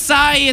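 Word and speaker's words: açaí: Acai. It's pronounced acai. – Cheney, yeah. – No, literally. – Yeah, accent on the eye.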